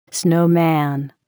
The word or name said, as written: snowman